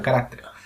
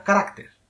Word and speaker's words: carácter: Se dice igual, carácter. Es voz llana y se pronuncia carácter, no carácter. En el plural, el acento prosódico pasa de la A a la E.